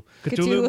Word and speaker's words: Cthulhu: Cthulhu, how do you pronounce it? – How I pronounce it probably isn't like how anybody else pronounces it. I always say Cthulhu. – Cthulhu.